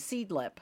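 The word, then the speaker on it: Seedlip: Seed, S-E-E-D, and then Lip, L-I-P, Seed Lip.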